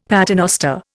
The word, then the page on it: paternoster: Indian